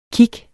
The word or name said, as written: kig